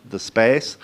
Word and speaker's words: The Space: You pronounce it with a northern accent, note, The Space, which is making available digital content in of varied kinds in across different platforms and in a new way.